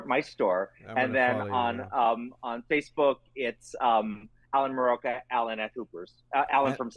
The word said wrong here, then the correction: Muraoka: And it's Alan, A-L-A-N. – A-L-A-N, yes. – At Hoopers. – Muraoka. Good luck. Muraoka, M-U-R-A-O-K-A. That is butchered all the time.